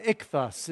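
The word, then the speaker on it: ichthys: And today we are going to be looking at the fish, or the ichthus is how you say it in Greek.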